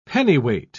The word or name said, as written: pennyweight